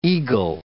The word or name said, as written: egal